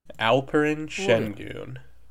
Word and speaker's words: Alperen Şengün: Alperen Şengün. Did I say that right? I practiced. – Alperen Şengün. – Alperen Şengün.